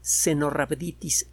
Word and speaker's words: Caenorhabditis: Este bichito pequeño se llama Cenorhabditis elegans, se escribe Caenorhabditis con B grande, elegans.